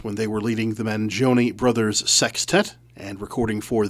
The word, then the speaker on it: Mangione: Note that I'm pronouncing the name Mangione. A lot of people mispronounce it, Mangione.